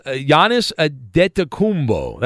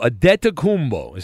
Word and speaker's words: Antetokounmpo: Antetokounmpo is how you pronounce it. – Like paying a debt? – It sounds similar to that, but there's no B.